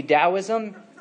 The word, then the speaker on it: Taoism: Some of the other sources of the New Age movement would be Taoism, spelled with a T but pronounced with a D.